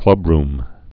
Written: clubrooms

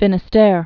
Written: Cape Finisterre

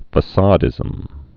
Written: facadism

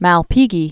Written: Marcello Malpighi

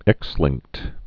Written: X-linked

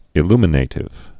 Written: illuminative

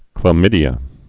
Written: chlamydia

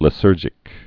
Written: lysergic acid

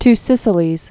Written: Two Sicilies

